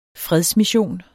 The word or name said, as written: fredsmission